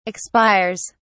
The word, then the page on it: expires: British English